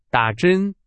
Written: 打针

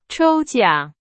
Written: 抽奖

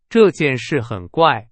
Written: 怪

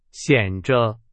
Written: 显著

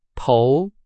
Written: 投

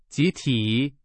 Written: 集体

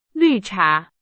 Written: 绿茶

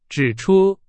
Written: 指出

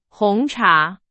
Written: 红茶

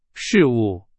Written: 事物

